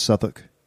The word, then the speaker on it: Southwark: Southwark is spelt Southwark. – Pronounced Southwark.